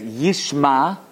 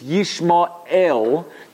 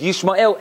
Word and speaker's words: Yishmael: It's Yishmael. It doesn't matter by Ashkenazim because we can't pronounce an ayin and the aleph doesn't mean anything anyway. But it's pronounced as Yishmael as if there's no aleph.